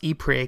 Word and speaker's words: Ypres: So I have been pronouncing the name as Ypres, which is the French name for the city. But the city is actually located in the Flemish region of Belgium, and I am told they pronounce it as Ypres. and it is spelled quite differently.